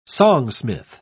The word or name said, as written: songsmith